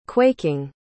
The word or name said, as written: quaking